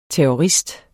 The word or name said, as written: terrorist